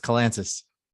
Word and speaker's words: Kalantzis: It's Kalantzis. Kalantzis. Gotcha. Uh, like Atlantis, right? Okay, with the K.